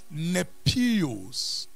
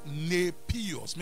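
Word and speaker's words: Nepios (νήπιος): And mind you, the Greek word that has been translated child here, we have mentioned it in this house before, is nepios. The phonetic is N-A-Y-P-E-E-O-S. Nepios. Maybe let's pronounce that way. Nepios. Spelled N-E-P-I-O-S. Nepios.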